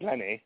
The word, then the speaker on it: Lenne: And he has a new band now called Lenny. It was just L-E-N-N-E. It's pronounced Lenny, but yeah, there wasn't any.